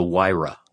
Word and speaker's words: Huayra: It's called the Huayra.